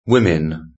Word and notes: women: British English

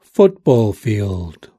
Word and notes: football field: British English

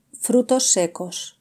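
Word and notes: frutos secos: Spanish (Spain)

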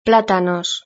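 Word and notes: platanos: Spanish (Spain)

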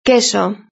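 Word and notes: queso: Spanish (Spain)

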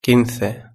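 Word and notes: quince: Spanish (Spain)

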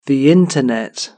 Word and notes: the internet: British English

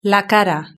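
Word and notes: la cara: Spanish (Spain)

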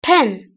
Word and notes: pen: British English

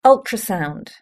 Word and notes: ultrasound: British English

